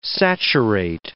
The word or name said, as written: saturate